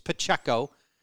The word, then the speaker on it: Pacheco: I botched his name. It's Pacheco.